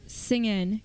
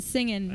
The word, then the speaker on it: singin': This is an 8-track without its cover called Everybody's Singin' Country. And singin' has no G. That's why I said singin'.